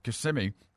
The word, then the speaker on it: Kissimmee: I thought he might have been from Lee County because he's from St. Cloud, Florida, which is by Kissimmee up that way.